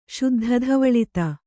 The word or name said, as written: ಶುದ್ಧಧವಳಿತ